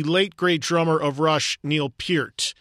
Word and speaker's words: Neil Peart: And yes, that is how you properly say his last name. I cannot tell you how many times I've heard people continue to mispronounce his last name, even in the weeks since we've lost him. His name is and was properly pronounced Neil Peart.